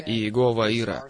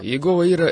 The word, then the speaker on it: Иегова Ира: Иегова Ира. Кит Мур говорит, что именно такое произношение правильное. Иегова Ира. Так что следует говорить так. Иегова Ира — это наш сверхъестественный обеспечитель.